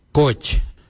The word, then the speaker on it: goodj: Garli is spelled G-I-I-L-Y-I, garli. For the traditional stone axe we say goj. Gotch. Gotch is spelled G-O-O-D-J. Gotch.